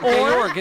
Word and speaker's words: Georg: Georg. It's German sort of pronouncing George. G-E-O-R-G.